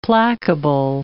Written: placable

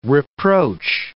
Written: reproach

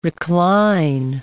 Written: recline

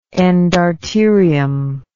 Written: endarterium

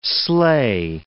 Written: sleigh

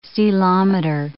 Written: ceilometer

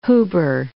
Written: Huber

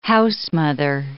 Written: housemother

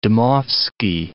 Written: Dmowski

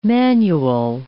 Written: manual

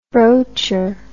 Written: broacher

